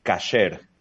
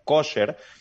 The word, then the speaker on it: kasher: Pero la palabra en hebreo es kasher, con A. Lo que pasa es que, como ustedes sabrán, hay dos formas de pronunciar el hebreo. La forma sefaradí, que es de los judíos de España, y también la forma de los judíos europeos, que la A la pronuncian O.